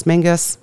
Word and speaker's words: Menzies: And it's spelled M-E-N-Z-I-E-S. So I was writing this and thinking it's Menzies, which sounds sort of diseasey or...